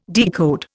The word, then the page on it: decode: Indian